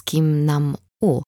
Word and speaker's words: Kim Nam-u: Wypowiedziałam twoje imię, odruchowo je przekręcając z Kim Nam-u. Zadziałała jakaś pierwotna część mojego umysłu. A ty jak zwykle. Tyle razy ci mówiłem, że nie tak się to wymawia.